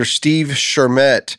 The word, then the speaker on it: Shermett: Actor Steve Shermett.